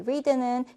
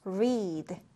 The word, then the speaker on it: read: 자, 우리 read는 현재형이요. read 이렇게 발음을 해주고 과거형 같은 경우엔 read 이렇게 발음을 해준다. 아, 스펠링은 같은데 발음은 read. read 이렇게 따로따로 기억해 주시면 되겠습니다.